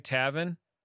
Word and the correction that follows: Taven: Taven. – Taven. – Like Raven, okay. But with a T. Taven.